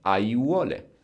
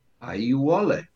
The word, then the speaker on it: aiuole: You don't need to know this word, but it's just an example that when we have four vowels, a, i, u, o, close together, we just pronounce each and every one of them. Aiuole. – Aiuole.